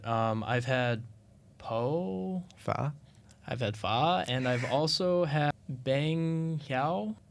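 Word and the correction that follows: bánh xèo: I can spell it out to you. It's B-A-N-H and then X-E-O. – Oh, it's banseo.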